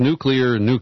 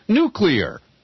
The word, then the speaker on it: nuclear: who somehow pronounce the word nuclear. And what is it about smart people who can't say nuclear? Now repeat after me 5 times.